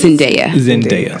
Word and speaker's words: Zendaya: Is her name pronounced Zendaya? – Yes. Zendaya.